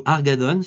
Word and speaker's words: Hargadon: Euh, moi, je suis un grand fan d'un type qui s'appelle Andrew Argadon. Ça s'écrit H-A-R-G-A-D-O-N qui enseigne à UC Davis,